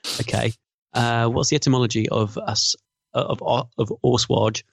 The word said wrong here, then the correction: assuage: How do they pronounce it? – Assuage.